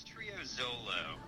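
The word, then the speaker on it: Trio Xolo: How do you pronounce the band's name? – Yeah, it's Trio Zolo.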